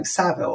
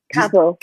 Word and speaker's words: Cavill: How do you say his last name? Saville? Or Cavill. – Oh, you say Cavill.